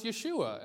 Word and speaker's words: Yeshua: And actually, the Hebrew for Joshua would be pronounced Yeshua. And if you are in Israel, you wouldn't call Jesus Jesus. You would call him Yeshua.